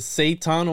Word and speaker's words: Seton: It was said Seton, almost like Satan.